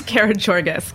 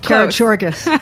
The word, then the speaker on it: Karagiorgas: Karagiorgis. – Karagiorgis.